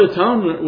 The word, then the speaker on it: atonement: The word atonement was not originally pronounced as atonement. The word atonement was originally pronounced as at-one-ment, which means that the covering provided in the blood of Jesus Christ brought God and the sinner together as one.